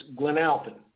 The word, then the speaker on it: Glen Alpine: My mother grew up in Glen Alpine, North Carolina, or if you're from up there, it's Glen Alpine. That's the way they pronounce it.